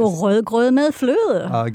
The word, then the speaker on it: rødgrød med fløde: Rødgrød med fløde.